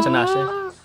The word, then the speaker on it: Tinashe: Tinashe.